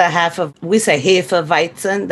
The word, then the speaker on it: Hefeweizen: Now, if you drink the Hefeweizen, that's, you know, the way you pronounce it.